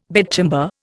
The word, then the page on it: bedchamber: Indian